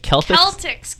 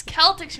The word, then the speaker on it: Celtics: The Celtics? – Celtics. You want to pronounce the C. Americans don't do it. It's wrong.